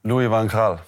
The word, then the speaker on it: Louis van Gaal: Louis van Gaal.